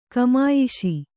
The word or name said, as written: ಘಮಾಯಿಸು